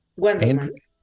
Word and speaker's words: Guendelman: Guendelman. – Andrea Guendelman. – Yes. – Almost like a Q at the beginning.